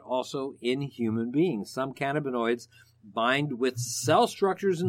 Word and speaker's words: cannabinoids: Well, it's one of over 100 chemical compounds that are called cannabinoids. Actually, did I pronounce that right? Cannabinoids, actually. – Yes, that's good.